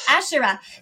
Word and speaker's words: Asherah: How do you pronounce that? – Asherah.